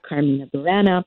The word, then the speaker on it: Carmina Burana: It's pronounced many different ways. Your way was fine. I pronounce it Carmina Burana.